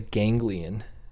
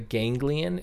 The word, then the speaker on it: ganglion: You know, when we used to use blimps, back in the day, I developed a ganglion. A ganglion. It's spelled gang lion.